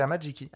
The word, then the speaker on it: Amajiki: Amai Jiki Tamaki. – Amajiki, hein, je crois que c'est Amajiki.